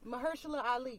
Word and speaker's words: Mahershala: How do you say the nigga name? – Mahershala Ali.